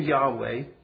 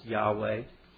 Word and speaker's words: Yahweh: Yahweh is a Hebrew word, probably pronounced Yahweh. Reverent Jews wouldn't pronounce this name, and so the exact pronunciation is not exactly known.